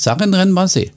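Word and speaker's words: Sarenren Bazee: Denn äh, ja, ich glaube auch, diese, diese Jungs, unsere, unser, unser Sarenren und auch, wie, wie sagt man denn eigentlich zu ihm? Sarenren Basé oder wie spricht man Ja, der, der heißt Sarenren Basé.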